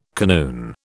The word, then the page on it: kanoon: British English